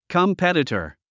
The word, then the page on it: competitor: kəmpétətər; カンペテタァ